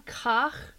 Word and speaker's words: Koch: He pronounces it Koch. It's spelled like Coke, okay. But yeah. John, I already forgot what I said. Koch. It's just such an ugly pronunciation. It's Koch without the R.